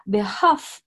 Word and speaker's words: behalf: Обратите внимание, если вы это произносите слово вслух, behalf L не читается в слове half.